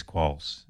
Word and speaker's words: quolls: So let's talk about your research with the quolls. – Okay, so... – And I'm saying it right again. – Yeah, right? – Quolls, yes. Quolls.